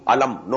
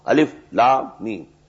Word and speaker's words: Alif Lam Mim: These are pronounced separately, and so they are called, they are the broken into pieces. Not pronounced as Alif Lam Mim Alam, no. Alif Lam Mim. Alif Lam Ra Alar, no. Alif Lam Ra. Now the meanings of these broken alphabets, exact meanings we don't know.